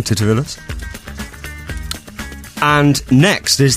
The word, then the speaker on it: Titivillus: In the Middle Ages, mistakes and spelling errors made while writing were blamed on a typo demon named Titivillus.